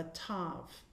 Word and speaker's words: tav: And it's also called a tav, T-A-V, although we've never seen it anywhere pronounced that way, but it is also called a tav.